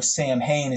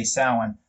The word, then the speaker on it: Samhain: So Samhain is how like a lot of people pronounce it because they don't know it's you're supposed to say Samhain.